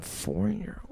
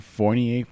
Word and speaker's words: Fournier: Evan Fournier. – Fournier. You're close. Evan Fournier. Same difference.